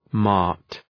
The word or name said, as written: mart